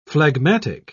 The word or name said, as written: phlegmatic